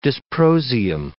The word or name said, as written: dysprosium